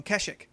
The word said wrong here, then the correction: Kashyyyk: Oh, Kashyyyk. Little Kashyyyk. Close. Very close. – I think that's a cereal my grandmother is. – That's Kashyyyk, but it's okay.